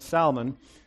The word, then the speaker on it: Salmon: In case you're wondering, yes, Salmon is the proper Hebrew pronunciation, okay? You can debate whether or not that's the right way to say the fish, but for the Hebrew name, it's Salmon.